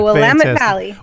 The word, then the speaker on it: Willamette Valley: Is it Willamette Valley? Did I say that right? – You said it. The Willamette Valley. The Willamette Valley.